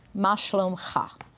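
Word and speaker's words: Ma shalom ha (מה שלומך): And here's how you say it in Hebrew. Ma shalom ha.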